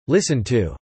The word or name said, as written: listen to